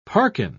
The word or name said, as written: parkin